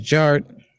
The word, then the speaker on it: Jart: And my name is Jared, aka DJ Art, with two T's for a double dose of that tink tink. The D is silent, so it's just Jart.